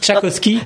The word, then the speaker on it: Tchaïkovski: Tchaïkovski, pardon, je le prononce bien, c'est pas forcément évident. Tchaïkovski.